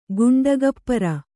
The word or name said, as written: ಘಂಟಾಗೋಪುರ